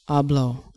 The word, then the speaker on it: Abloh: yesterday, Virgil, is it Abloh? – Abloh.